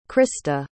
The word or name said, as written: crysta